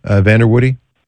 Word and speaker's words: Vanderwoody: And the last name is Woody, Vanderwoody. That your pronunciation? – It is, yes.